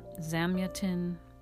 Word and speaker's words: Zamyatin: And this is by Eugenie Zamyatin, Zamayat, Zamayatin, um, Zamyatin.